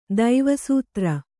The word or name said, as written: ದೈವಸೂತ್ರ